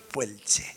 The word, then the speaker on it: puelche: el pueche. ¿Se dice así? Puelche. Pueche.